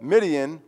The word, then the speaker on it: Midian: If you want to remember primarily the larger part of that army, remember that Midian rhymes with Gideon.